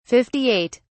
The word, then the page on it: 58: British English